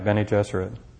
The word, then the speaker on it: Bene Gesserit: I'm sure not pronouncing it correctly. The Bene Gesserit? – Yeah, Dad said they're like female Jesuits. So you're right, Bene Gesserit.